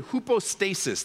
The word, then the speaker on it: hypostasis: You can circle that word and you can write next to it, hypostasis.